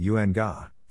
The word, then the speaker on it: UNGA: For starters, UNGA, acronym, yes, people do pronounce it UNGA, for the UN General Assembly's high-level week.